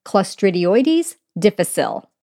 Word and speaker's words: Clostridioides difficile: It's really hard to say, and it's Clostridioides difficile.